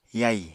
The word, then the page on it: yai: Apatani